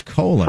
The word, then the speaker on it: Colon: Cologne, maybe? – It's even phonetically spelled out. It is colon.